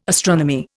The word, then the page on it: astronomy: Indian